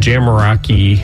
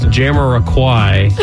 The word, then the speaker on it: Jamiroquai: someone called me one time and they were like, "You're saying it wrong. You're saying Jamiroquai." They said it's Jam. They're like, "I don't want to be that guy, but it's Jamiroquai." So, and we just got a caller.